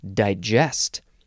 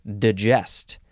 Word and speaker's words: digest: The next word is the second form of the same word, but here we pronounce it digest, or just digest.